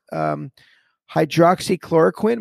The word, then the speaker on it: hydroxychloroquine: But I believe he took one of those things, hydroxychloroquine.